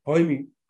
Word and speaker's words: Jaime: And I have these stats here, and it's from a former podcast guest you had on, Jamie Segura. Is that how you pronounce his name? – Jaime Segura.